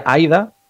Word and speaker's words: Ida: Pero la historia trata sobre una joven que se llama Ida, ¿vale? Que en pronunciación sería Aida,